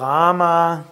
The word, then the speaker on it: Ram: Für Rama gibt es Ram, und zwar lang ausgesprochen. Ram kurz ausgesprochen ist Feuer.